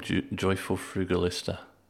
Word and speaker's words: Joyful Frugalista: How did you come up with the name Joyful Frugalista? Am I saying that right? – Yeah, you are indeed. You're saying it exactly right.